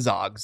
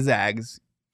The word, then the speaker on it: Zags: They're not the Zogs, they're the Zags.